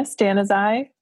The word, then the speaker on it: Stanizai: It's Sara Stanizai, like stand aside.